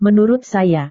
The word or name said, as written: menurut saya